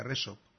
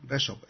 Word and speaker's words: Rexob: Resop. R-E-X-O-B. – Resop.